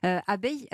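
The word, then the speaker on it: Abeye: Abeille, on dit abeille, mais ça s'écrit comme un œil, E-Y-E.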